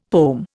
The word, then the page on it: pome: Indian